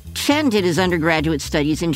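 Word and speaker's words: Tsien: Chen, his Chinese name will be pronounced Chen Shushen. So Shushen will be his first name, and his last name is spelled when he was in the U.S., T-S-I-E-N, Chen.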